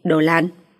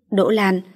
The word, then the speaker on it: Đỗ Lan: Mồm miệng vẫn độc như cũ nhỉ, đồ lan. Rốt cuộc gọi ra tên người ta, nhưng lại cố ý phát âm không đúng. Đỗ Lan, lại phát âm giống đồ lạn, nghĩa là thối nát.